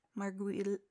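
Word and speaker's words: Margulies: So since we're kind of talking about characters right now, Juliana Margulis. Margulis? – That's it. Nailed it.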